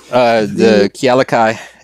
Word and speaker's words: Kealakai: Well, as we know with the Kealoha, is that the correct pronunciation? The Kealakai.